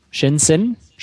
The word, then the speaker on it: Shenzhen: in Shenzhen, spricht man das so aus? Ja, ja, das spricht man so aus.